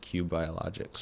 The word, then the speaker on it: Qu Biologics: Yeah, it's spelled QU Biologics. I think pronounced Q Biologics.